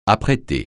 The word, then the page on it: apprêter: francais